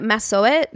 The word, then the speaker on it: Massasoit: How did you say it? – Massasoit. – Massasoit. – I think that's how they said it.